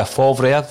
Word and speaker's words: Favre: We spoke about Lucia Favre, that's how you pronounce it, Declan, former Borussia Dortmund manager.